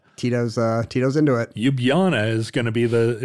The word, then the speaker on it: Ljubljana: Ljubljana, much, much yeah.